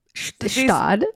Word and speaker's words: Gstaad: How do you say it? Stad? – Stad. – Stad is the G silent? – Yeah.